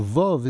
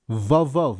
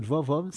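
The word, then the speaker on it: vav (ו): The vov is vav. We pronounce it vav, 6 by 6.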